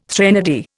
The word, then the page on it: threnody: Indian